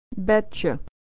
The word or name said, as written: betcha